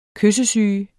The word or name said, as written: KYSSESYGEN